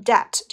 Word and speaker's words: debt: 那一定要知道 then 前面肯定要接一个比较等级，也就是说他们的花销通常比他们自己挣的还要多，所以呢他们许多人都会怎么样 in debt, 这里面的字母 B 是不发音的啊，会欠债的。